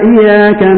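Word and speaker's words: Iyyaka (إِيَّاكَ): Kalau seseorang baca Al-Fatihah kalau sampai hilang tasdidnya, apa? Ini salah fatal. Iyyaka na'budu wa iyyaka nasta'in, salah fatal, Bapak. Salah besar. Bagaimana membacanya? Membacanya dengan ditekan, Bapak.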